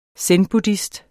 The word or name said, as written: zenbuddhist